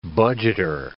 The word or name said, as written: budgeter